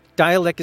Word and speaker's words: Dälek: Dialek is the band, and Dialek is spelled D-A with umlauts over the A, D-A-L-E-K.